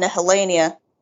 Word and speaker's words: Nihilania: Like during that time, with the villain Nihilania, which is, you were right, you pronounced it absolutely correct.